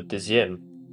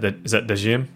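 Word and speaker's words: deuxième: The Demu file. I really don't know how to say it. Like Deuxieme. – Oh, Dezim. – Is that Dezim? – Yeah, that's like the 12th.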